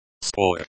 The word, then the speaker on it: спор: Спор. Pronounced спор, like Spore, with the emphasis on the first syllable, means dispute or argument.